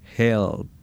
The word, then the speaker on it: HELLP: Se escribe casi como si fuera ayuda. En inglés, H-E-L-L-P, help.